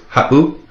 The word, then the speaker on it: ha7u: If you listen to that word ha'u, ha'u, it would be spelt H-A-7-U. If you were to take out that seven, it would be pronounced ha'u. With the seven in there, makes that little glottal stop, that pause.